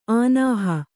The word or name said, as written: ಆನಾಹ